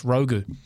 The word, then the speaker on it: Rogue: It's actually pronounced Rogu. – Thrilling Ragu or Rogu. – It's spelt Rogue, if you're trying to find it on the TAB, but pronounced Rogu because that the parents... of that particular dog, actually of Portuguese descent.